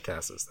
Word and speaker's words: Casas: Casas? Oh boy, guys, I can't pronounce anyone's name. – I've heard it. Casas? – Yeah, Casas. That sounds right. I'll go with that.